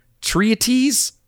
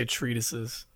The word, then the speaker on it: treatise: Treatise? – I would say treatises. – But there's not two. You're really bad. – Treaties? – Like, you're really bad.